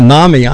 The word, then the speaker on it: NAMI: Nani? – For Nami, I'm sorry. Nami.